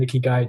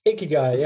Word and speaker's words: Ikigai: Have we said Ikigai right? That's how we pronounce it? – Ikigai, yeah, Ikigai, I think, yeah.